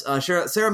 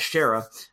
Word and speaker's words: Sarah: But nonetheless, Sarah Michelle Gellar, which I usually pronounce as Shara. I don't know why, but I always do. But that's incorrect for the record.